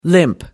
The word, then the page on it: limp: American English